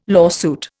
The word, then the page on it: lawsuit: Indian